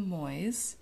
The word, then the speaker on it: Moyes: The Giver of Stars by Jojo Moyes. I mainly listen... Caleb's laughing because I found out that it's pronounced Moyes and not Moyes.